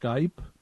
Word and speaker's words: Skype: And did you hear how she pronounced Skype? Listen carefully. He was her first Skype ever. – You were my first Skype ever. Skip.